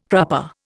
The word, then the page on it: crupper: Indian